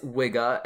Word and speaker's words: Wigge: It is designed by Matthias Wigge, and yes, his last name is pronounced that way. It's spelled W-I-G-G-E.